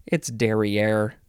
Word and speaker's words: derrière: It's derrière. Of course we had to get to derrière. D-E-R-R-I-E-R-E.